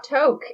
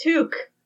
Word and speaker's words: toque: So it's actually not toke, even though I feel like looking at that, I still read it as toke. Looks like it's actually pronounced toque.